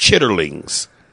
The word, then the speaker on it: chitterlings: And it's pronounced chitterlings. But, you know, black folks, chitlins, baby.